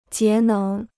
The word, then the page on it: 节能: jié néng